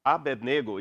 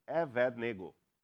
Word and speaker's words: Evednego: Abednego is how we pronounce it, but in Hebrew is Evednego.